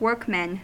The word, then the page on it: workmen: American English